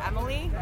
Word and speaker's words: Emily: Sure, Emily, E-M-I-L-Y.